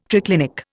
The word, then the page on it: triclinic: Indian